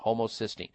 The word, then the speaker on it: homocysteine: Homocysteine, just phonetically sound it out, homocysteine.